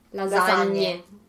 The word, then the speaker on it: lasagne: How do you say it properly? – Lasagne.